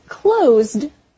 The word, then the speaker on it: closed: Also, notice the pronunciation. Without the D, close is very short, with an S sound at the end. Close. But the word closed, with a D at the end, is stretched longer, with a Z sound.